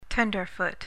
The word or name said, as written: tenderfoot